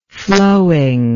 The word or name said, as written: flowingness